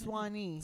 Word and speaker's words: Swany: And I mean, people would pronounce my name horribly wrong. – How? What were the pronunciations? – I got Swany, Svanky, Swany. – Swany? – But it's Swany. – Swany. – With a Y.